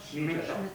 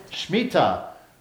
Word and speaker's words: Shmita: This is the Shimta practice. I don't know if I've spelled or pronounced that correctly. Shimta? – Shmita. – Shmita. Wow, not even close.